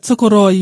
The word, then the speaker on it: Tokoroa: You can say it how you want, but Tokoroa, you roll your R a little Tokoroa.